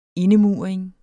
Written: indemuring

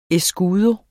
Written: escudo

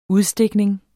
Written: udstikning

